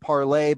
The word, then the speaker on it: Parler: And I think where some of the anger we're seeing now is that Amazon's web services did shut down Parler, which, by the way, was actually meant to be pronounced parlay, but Americans pronounce things as they're spelled. So we, really couldn't get around no, I didn't, the, I didn't, parlay.